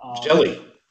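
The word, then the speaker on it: Hjelle: I know that Sean, how do you say his last name? Is it Hagel? – Jelly. Sean Jelly.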